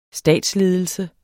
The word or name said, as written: statsledelse